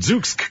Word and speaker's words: Zoosk: They're Z-O-O-S-K, so how else would you pronounce it? Zooks.